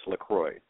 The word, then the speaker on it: LaCroix: If we were in France or Canada, they would say LaCroix, but here it's LaCroix, like the island of Saint Croix.